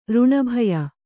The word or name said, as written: ಋಣಭಯ